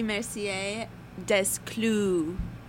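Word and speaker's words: Mercier Descloux: And then before that, you heard Funky Stuff by Lizzie Mercer DeClaude. How do you say her name? – It's Lizzie Mercier DeClaude.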